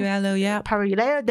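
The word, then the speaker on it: Paralluelo: Paraleo? Is that how you pronounce it? – Paraleo, there you go.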